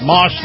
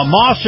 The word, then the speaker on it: Maus: This portion of the program brought to you by my very good friends at Moss Nissan. Now, Moss is spelled M-A-U-S, Moss Nissan.